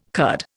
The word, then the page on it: curd: Indian